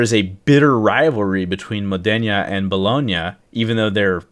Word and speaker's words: Modena: Modena, whatever.